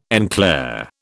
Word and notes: en clair: British English

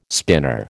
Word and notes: spinner: American English

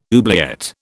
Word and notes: oubliette: British English

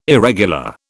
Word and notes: irregular: British English

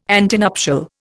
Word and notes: antenuptial: Indian

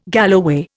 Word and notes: galloway: Indian

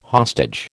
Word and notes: hostage: American English

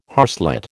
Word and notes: harslet: American English

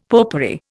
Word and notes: popery: Indian

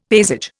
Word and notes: paysage: Indian

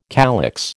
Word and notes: calyx: American English

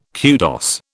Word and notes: kudos: British English

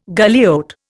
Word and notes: galliot: Indian

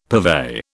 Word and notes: purvey: British English

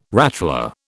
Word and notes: rattler: British English

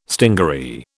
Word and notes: stingaree: British English

